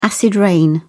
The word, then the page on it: acid rain: British English